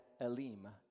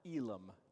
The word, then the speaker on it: Elim: That's going to be the purple star, and then finally, the chapter is going to end with them arriving at Elim, or Elam, depending on how you want to pronounce that, Elim, and that's the purple circle there.